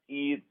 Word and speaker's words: Ives: But you've got somebody coming up this weekend at the, it's called A Cabaret with Eric Ives Garcia. Am I pronouncing his name correctly? – It's Eric Eves Garcia. – Eves, like Eve Montan, the actor.